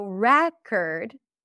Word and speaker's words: record: I bought a new record. The primary stress is on the first syllable. And then this is going to be a schwa.